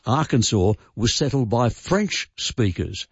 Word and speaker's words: Arkansas: So Kansas is pronounced Kansas, but the one next door, Arkansas, is pronounced Arkansas.